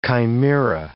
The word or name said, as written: chimaera